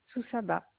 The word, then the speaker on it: Soussaba: Soussaba, avec un S.